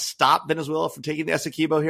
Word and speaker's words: Essequibo: Did I say that right? Les, I know you know how to pronounce it. But Essequibo, thank you. Essequibo. I got it right earlier and I got it wrong this time.